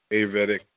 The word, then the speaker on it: Ayurvedic: Ayurvedic.